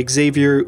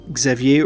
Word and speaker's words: Xavier: Euh, au début, elle demande justement comment se prononce mon nom, parce qu'en anglais, c'est soit Xavier ou Xavier ou Xavier, plusieurs prononciations possibles.